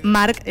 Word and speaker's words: MVRK: Xema Seda sí, pero por ejemplo, se le complicaba más, pues mira, este artista que se escribe MVRK, se dice Mark, estará este sábado en concierto en el Mariña Sound.